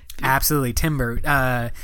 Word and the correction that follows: timbre: you- it's actually pronounced like timbre or something.